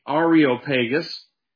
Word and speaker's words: Areopagus: I believe it's pronounced the Areopagus, but the Roman title for this hill that you saw in the pictures is called Mars Hill.